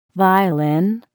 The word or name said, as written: violin